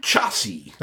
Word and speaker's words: Xiasi: No, this one's called the Chassi. It's spelled capital X-I-A-S-I. – Say that again. – Chassi. That's the way it's pronounced. – Spell it. – X-I-A-S-I.